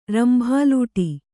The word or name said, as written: ರಂಭಾಲೂಟಿ